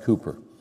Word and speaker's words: Cowper: His name is spelled Cowper, so it looks like Cowper, but it's pronounced Cooper.